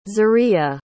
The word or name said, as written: zuriaa